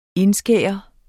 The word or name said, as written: INDSKAR